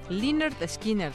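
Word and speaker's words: Lynyrd Skynyrd: Él fue bajista del grupo de rock sureño Linbright Skinright. Leonard Skinner. Leonard Skinner, aunque tenga Y, así, se pronuncia.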